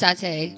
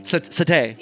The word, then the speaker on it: Satie: Sorry, I say it the like trap version, but it's Satie. – Satie.